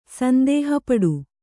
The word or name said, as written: ಸಂದೇಹಪಡು